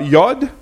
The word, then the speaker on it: yod: A jot is the letter Y, pronounced uh, yod, and is the smallest letter in the entire alphabet.